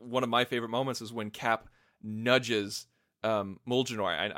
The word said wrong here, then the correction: Mjolnir: I'm probably not pronouncing Mjolnir. – Mjolnir. Thank you. I always pronounce the J, and I know I shouldn't.